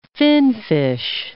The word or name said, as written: finfish